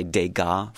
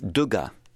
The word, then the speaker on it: Degas: We say Degas, for example. What's another in French Monet. – Name? So it's Monet and it's Duga in French because there is no accent on that E.